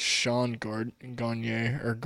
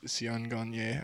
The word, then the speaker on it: Sion Garnier: It looks like Sean Garnier or however you say it, but it's Sion Garnier, I believe, is the correct pronunciation.